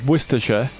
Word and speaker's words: Worcestershire: Okay, I researched it. Worcestershire.